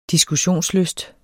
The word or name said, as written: diskussionslyst